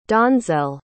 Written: donzel

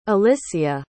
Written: alyssia

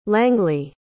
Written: langleah

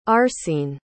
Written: arcene

